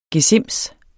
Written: gesims

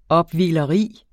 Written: opvigleri

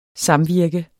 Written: samvirke

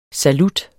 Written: salut

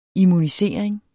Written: immunisering

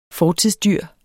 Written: fortidsdyr

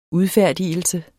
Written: udfærdigelse

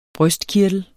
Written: brystkirtel

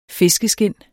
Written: fiskeskind

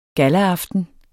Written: gallaaften

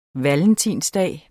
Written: valentinsdag

